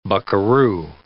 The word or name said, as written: buckeroo